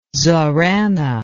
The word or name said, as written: zorana